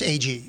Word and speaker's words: Agee: Steve, how do you say his last name? Agee? – Like two A-G. Letters. Agee, yeah.